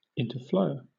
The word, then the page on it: interflow: British English